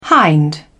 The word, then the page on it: hind: British English